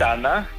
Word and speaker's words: Tana: Tana, ¿he pronunciado bien la localidad? – Sí, bien. Tana.